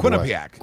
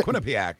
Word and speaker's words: Quinnipiac: How do you say that, by Quinnipiac. – The way? – Quinnipiac. It's an Indian name, I believe.